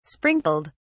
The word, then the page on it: sprinkled: British English